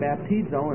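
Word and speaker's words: baptizo (βαπτίζω): The Greek word is spelled B-A-P-T-I-Z-O, baptizo.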